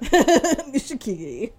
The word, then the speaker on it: Nishikigi: Next up, we have Meisei and Nishiki Kigi. I think I added an extra syllable in there. – You did, but it's all good. I was going to let that ride. – Nishikigi.